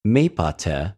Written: μηποτε

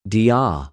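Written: διο

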